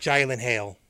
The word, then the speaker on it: Gyllenhaal: It's pronounced Gyllenhaal.